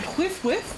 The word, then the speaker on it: Hwith: Am I saying that right? Hwith? – Yeah. – This village is called Hwith, right? – Yeah. – Hwith? – Like Hwith? Calm and wind.